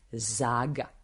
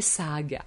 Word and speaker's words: Zaga: Šveđani nemaju Z, oni S, apsolut, sve što je naše Z, Zlatan je Z, Slatan, oni ne znaju Z da izgovore i nema ga, Zeta ne postoji kao u jeziku švedskom. Odjednom žena Šveđanka 100% se zove Zaga, nije Saga.